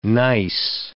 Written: gneiss

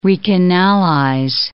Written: recanalize

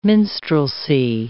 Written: minstrelsy

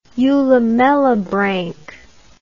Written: eulamellibranchiate

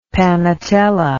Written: panatella

